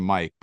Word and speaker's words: Myq: It's the guy who pronounces his name Mike, but it's spelled M-Y-Q.